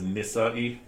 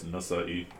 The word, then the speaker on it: Nasa'i: Yeah, some people mispronounce it as Nisa'i, so it's Nasa'i.